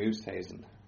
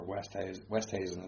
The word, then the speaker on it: Oosthuizen: I'm going to go for Louis Oosthuizen, or Westhuizen as he pronounces it. – Is it Westhuizen? – He says the Westhuizen.